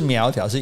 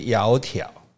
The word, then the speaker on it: 窈窕: 那其实不是苗条，是窈窕。嗯，哦，是一个穴，然后底下一个又，然后穴底下一个己造的造，这两个字是念作窈窕。